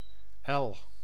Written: yard

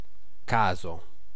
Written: haphazardly

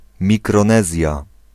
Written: Micronesia

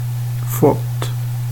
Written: fått

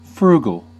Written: frugal

